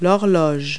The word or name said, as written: clock